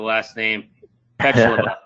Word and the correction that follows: Pejlova: is that how you say the last name? – That's pretty close. It's Pejlova, but that was close. – Pejlova.